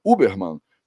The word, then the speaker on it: Huberman: Porque o Uberman, né, que é como eu deveria pronunciar o nome dele com um sotaque bem português, né?